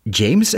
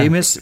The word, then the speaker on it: Jeymes: Eh, ja, James is het uitgesproken, denk ik. Samuel. James is dus J-E-Y-M-E-S.